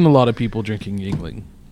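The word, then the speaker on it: Yuengling: This is Yingling. – Yingling.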